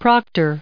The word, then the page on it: proctor: proc*tor; American English